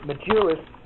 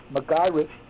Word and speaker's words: Magirus: Germany had two, Magirus, and I always called it Magirus, but you go there and you learn the proper pronunciation of some of this stuff. It's Magirus and Klaus.